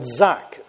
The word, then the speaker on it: zaaq: The Hebrew word for cried out is the word zak, Z-A-A-Q, if you wanted to spell it in English.